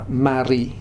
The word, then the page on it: Mari: Futuna